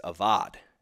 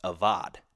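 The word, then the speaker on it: avad (עבד): And the original word used here is avad. And it's spelled A-B-A-D. Okay, a Hebrew word pronounced avad.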